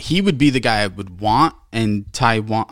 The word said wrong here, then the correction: Taillon: What is it? Taiwan? – Hold on. – Taiwan? – Taillon.